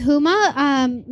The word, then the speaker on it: Huma: Hi, my name is Huma. My name is spelled H-U-M-A, short and sweet, just like me.